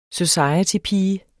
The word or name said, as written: societypige